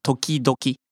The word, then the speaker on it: 時々: And the next Chinese character that repeats the previous kanji, so toki, toki. But you have to pronounce the latter toki, so doki, so toki doki.